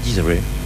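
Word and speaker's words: Des'ree: Et c'était donc Desiree avec le titre Life. Ça date de 95. – Desiree. – Desiree. Ah non, ça s'écrit D-E-S apostrophe R2-E. – Donc ça se dit Desiree.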